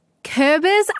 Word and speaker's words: Kürbis: Did you talk with a real, live, present-day Kürbis? I'm really trying my best to say it right. Also, ich meine, the person, not the pumpkin. – Du hast es sehr gut ausgesprochen.